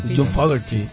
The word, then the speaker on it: Fogerty: ¿Qué pronunciación además, no? ¿Qué pronunciación? Muy bien. – John Fogerty. – Sí.